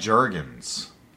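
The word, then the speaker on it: Jurgens: Dan Juergens. – You know Juergens? I like to. I think it's soft J.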